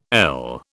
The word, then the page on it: ell: British English